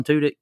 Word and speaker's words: Tudyk: Alan Tudyk? – Yes.